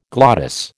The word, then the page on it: glottis: American English